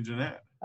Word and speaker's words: Gennett: You like Jeanette or Gennett? – I mean, there's only one right way, so I like the right way. – Is it definitely Jeanette? – Yes, it's definitely Jeanette.